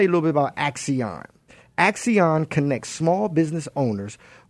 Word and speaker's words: Axion: Is spelled A as in apple, C as in cat, C as in cat, I, O, N as in Nancy. Axion.